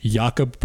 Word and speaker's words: Jakob: Pronunciation is Jakob Poeltl. So Y-A-K-U-B. And then Poeltl, P-U-R-R-T-U-H-L. That's how you say it.